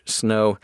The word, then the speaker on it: Snoh: Snoh, Aalegra is the singer, and her first name is spelled S-N-O-H, Snoh. And Aalegra has two A's at the beginning, A-A-L-E-G-R-A, Snoh, Aalegra.